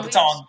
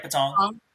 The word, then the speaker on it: Patong: I know I was in Pantone Beach, and then did I say that right? – Yeah. No, Patong. You're right, Patong.